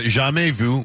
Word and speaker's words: jamais vu: It is French. How would you pronounce that right there? Because I do not know. – Oh, it's a jamais vu, which means never seen.